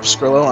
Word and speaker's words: Pescrillo: And for me, you can find me at Paul Pescrillo on Twitter.